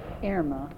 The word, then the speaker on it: Irma: Irma. – Irma. – My mother said it from the old German name, Irmgard.